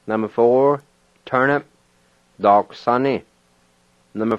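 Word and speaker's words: daquisani: And the U-I is silent. You just kind of sound out the Q sound. So here's how it sounds. Dark sunny. Dark sunny.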